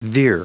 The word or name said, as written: veer